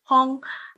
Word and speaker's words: Huong: So they realised that it was quite difficult for others to pronounce my Chinese name, Hong. People got it wrong. It's actually spelled H-U-O-N-G, which is actually a Vietnamese spelling of Hong, which is Hong in Hong Kong, as in H-O-N-G.